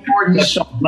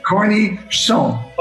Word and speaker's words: cornichon: Cornichon. – Like, that's a corny dude. Cornichon? – Yeah. Cornichon.